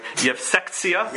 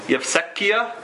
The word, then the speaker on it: Yevsektsia: They were known as the, I could pronounce this right, Yevsektsia. Did I say that right? – Yevsekia. – Or Tsia? There's a T and S in there also.